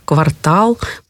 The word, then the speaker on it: квартал: Ударение обязательно будет падать на последний слог. Поэтому слова «дефис», «диспансер», «еретик», «жалюзи», «каталог», «некролог», «квартал», «портер», «портфель», «эксперт», я думаю, запомнит каждый.